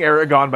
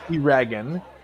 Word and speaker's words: Eragon: I mean, first there's Eragon, which you would not think of pronouncing Aragorn by looking at it, by the way. – Okay. – It's Eragon.